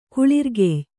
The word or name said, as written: ಕುಳಿರ್ಗೆಯ್